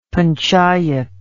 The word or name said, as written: panchayat